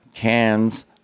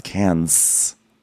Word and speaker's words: Cairns: Cairns, pronounced with an S at the end, as we learned from many people over the past week. I'm not actually in Australia. I'm in Los Angeles, but just wanted to point out that we learned that it's pronounced Cairns.